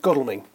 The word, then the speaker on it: Godalming: it causes some confusion amongst amongst people, but no, it's Godalming.